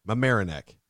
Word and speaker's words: Mamaroneck: Mamaroneck.